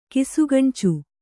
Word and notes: ಕಿಸುಗಣ್ಚು: kisugaṇcu

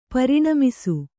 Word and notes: ಪೂರ್ಣಮಾಸ: kannada

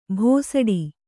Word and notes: ಭೋಸಡಿ: bhōsaḍi

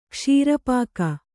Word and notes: ಕ್ಷೀರಪಾಕ: kṣīra pāka

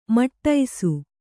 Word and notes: ಮಡಸಿ: Kannada

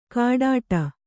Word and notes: ಕಾಡಾಟ: Kannada

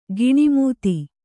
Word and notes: ಕಣ್ಣುಮೆತ್ತು: Kannada

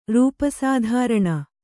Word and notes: ರೂಪಸಾಧಾರಣ: kannada

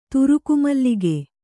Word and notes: ತುರುಕುಮಲ್ಲಿಗೆ: kannada

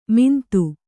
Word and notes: ಮಿಂತು: mintu

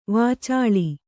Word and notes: ವಾಚಾಳಿ: vācāḷi

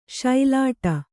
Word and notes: ಸೊಲ್ಲಿಡು: kannada

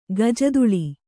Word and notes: ಗಜದುಳಿ: gajaduḷi

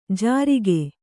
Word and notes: ಜಾರಿಕೆ: jārike

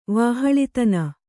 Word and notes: ವಹಿಲತನ: kannada

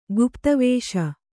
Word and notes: ಗುಪಿತವೇಷಿ: gupita vēṣi

